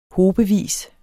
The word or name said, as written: hobevis